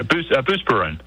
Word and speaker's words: Buspirone: Am I saying that correctly? Probably not. – Yeah, Buspirone.